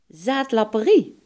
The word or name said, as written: dronkemanschap